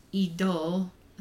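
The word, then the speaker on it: Idôle: It's called Idol, spelled like idol with an E at the end of it. But like American Idol, not like your car is idling.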